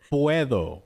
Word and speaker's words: puedo: Puedo. – Puedo. – No. – The D should be like a TH. So yeah. It's puedo.